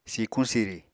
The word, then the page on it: sikuñ-siri: Apatani